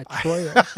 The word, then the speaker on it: Troyal: No, he was, he was born Troyal Garth Brooks. So his first name is spelled T-R-O-Y-A-L.